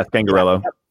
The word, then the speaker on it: Scangarello: And someone sent me an article of, I think it was Scangarello. Am I pronouncing that right? – Yeah, Scangarello.